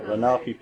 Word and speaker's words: Lenape: You pronounce it Lenape? – Yes. – Yeah, Lenape people are also called the Delaware Indians because they live near.